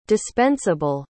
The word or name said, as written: dispensible